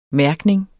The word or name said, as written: mærkning